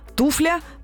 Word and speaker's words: туфля: А, в общем, туфля такое же, женского рода, с ударением на первом слоге туфля, она одна, значит, соответственно, чья туфля.